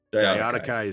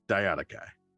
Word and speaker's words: Diadochi: But that's how we're going to say it. Diadochi. – Yeah. That was actually the first time I ever heard it pronounced, was Diadochi. And that's always how it's been. – That's how we'll do it. – And then I'll listen. Yeah. And I'll listen to it on, say, Kings and Generals or something on YouTube or History Marsh or whatever. And they'll pronounce it a completely different way.